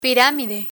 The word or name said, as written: piramide